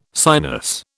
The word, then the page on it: sinus: British English